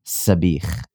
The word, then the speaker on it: Sabich: Sabich. S-A-B-I-C-H would be how it's phonetically spelled and pronounced.